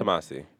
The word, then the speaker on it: Tomasi: I'm so glad that you pronounced your name for me because I was saying Thomas I. – Yeah, a lot of people do. Yeah. Or Thomas I. Like, Thomas I? – Yeah. – Like, I am Japanese and I'm not. – Yeah. – I'm very so Italian. It's Tomasi.